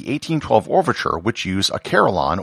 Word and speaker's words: carillon: On top of that, one of the instruments was a carillon, or as it's pronounced in England, a carillon.